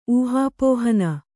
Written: ಊಹಾಪೋಹನ

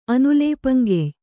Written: ಅನುಲೇಪಂಗೆಯ್

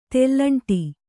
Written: ತೆಲ್ಲಂಟೆ

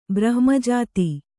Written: ಬ್ರಹ್ಮಜಾತಿ

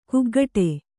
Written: ಕಿಗ್ಗಡೆ

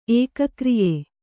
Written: ಏಕಕ್ರಿಯೆ

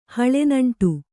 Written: ಹಳೆನಂಟು

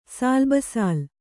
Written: ಸಾಲ್‍ಬಸಾಲ್

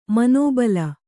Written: ಮನೋಬಲ

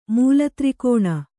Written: ಮೂಲತ್ರಿಕೋಣ

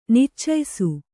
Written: ನಿಚ್ಚಯಿಸು

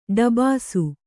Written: ಡಬಾಸು

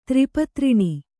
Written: ತ್ರಿಪತ್ರಿಣಿ

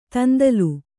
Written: ದುಂದುಲು

